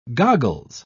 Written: goggles

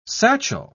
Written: satchel

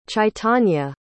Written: chaitanya

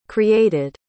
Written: created

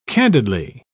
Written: candidly

